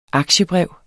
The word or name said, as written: aktiebrev